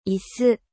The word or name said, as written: 椅子